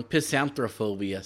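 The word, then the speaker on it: pistanthrophobia: so there's also a uh, piss out phobia. what? No, I said that wrong. Pisanthrophobia.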